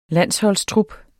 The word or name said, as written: landsholdstrup